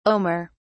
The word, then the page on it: omer: British English